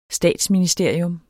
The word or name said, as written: statsministerium